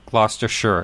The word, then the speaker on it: Gloucestershire: Well, we actually have someone in the chat room that's actually from Gloucestershire. And that's, well, I just said it the way it's supposed to be pronounced, Gloucestershire.